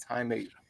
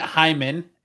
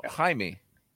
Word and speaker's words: Jaime: So when you have Jaime or Jamie, I'm like, what? It's Jaime. – They don't really use. – Isn't it Hyman? Isn't Jamie Hyman? – No, Jaime.